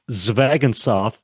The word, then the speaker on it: Zvegintzov: Uh, uh, thank you for having me first, uh, Joni, but it's Zvegintzov. Uh, it's just like it's spelled.